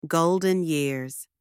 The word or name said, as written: golden years